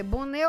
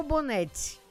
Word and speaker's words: bonet: Escreve B-O-N-E-T. Pode ser Bonnet ou Bonnette, né? Algumas têm diferença de pronúncia.